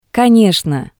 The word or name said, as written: Konetsno